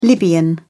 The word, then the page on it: libyan: British English